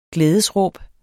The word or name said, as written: glædesråb